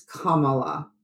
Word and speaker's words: Kamala: And it is Kamala, right? Not every time I settle on a pronunciation, someone tells me it's wrong. – It's Kamala. – Kamala. Okay. – Kamala Harris. – Because several people told me it's like Pamela with a K, and I just think that's wrong.